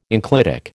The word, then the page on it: enclitic: American English